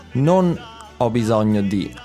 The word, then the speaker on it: non: So non, you have to make sure to pronounce the N at the end of that word because it's a little bit different from the word no.